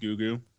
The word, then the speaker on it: Gugu: I believe her name is pronounced Gugu. – Gugu? – Like, phonetically, yeah.